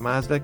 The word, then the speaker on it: Mazda: Mazda cars, notice the pronunciation, Mazda.